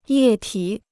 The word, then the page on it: 液体: yè tǐ